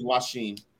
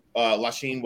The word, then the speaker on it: LaSheen: So really quickly, can you tell us how to pronounce your name? I keep saying LaShawn or LaSheen. I don't know. – My name is LaSheen.